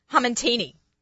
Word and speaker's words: hamantini: She's going to talk to us about this hamantini. – Yeah. – I said it right? – Yes. – I kept saying hamantashantini, but that was too much. Hamantini.